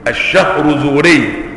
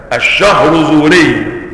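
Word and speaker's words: الشهرزوري: قال إلى أن جاء الحافظ الفقيه تقي الدين أبو عمرو عثمان بن الصلاح عبد الرحمن الشهر زوري، الشهر زوري تنطق هكذا، الشهر زوري، هنا مضبوطة الشين مضمومة، نعم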